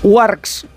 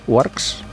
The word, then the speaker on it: Uarx: No quiere decir que sea una noticia irrelevante, no, pero como siempre se autorreferencia, mira, fijaos, Warx, ¿se dice así? Warx, U-A-R-X. – Es española, ¿eh? – Bueno, pero yo es que... – U-A-R-X. – U-A-R-X, vale.